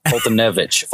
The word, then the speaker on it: Foltynewicz: Fulton Newitz. – Thank you, Mike Fulton. Yeah. Mike Fulton Newitz. Koiaharia. – I'm sorry, I got that wrong again. Foltynewicz. – Foltynewicz. Foltynewicz. Thank you.